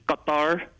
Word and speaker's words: Qatar: But I've heard people call it Qatar and Qatar. And when people try to make the noise, it ends up sounding like gutter. But it is Qatar, if you want to say it like an Arabic Qatar speaker.